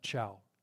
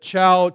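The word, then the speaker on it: Chau: I think it's pronounced Chow.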